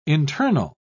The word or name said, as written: internal